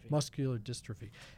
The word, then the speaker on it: muscular dystrophy: He was diagnosed the year before with muscle dystrophy. Did I say that right? – Muscular dystrophy.